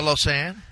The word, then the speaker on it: Lausanne: Yeah, Lausanne.